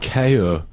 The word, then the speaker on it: kyu: I think the word Q, KYU, is pronounced KO.